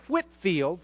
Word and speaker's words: Whitefield: George Whitfield, spelled Whitefield, but pronounced Whitfield.